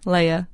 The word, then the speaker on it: Leia: We're not naming this baby Leah either. Leia, sorry. Don't write a letter. I mispronounced it.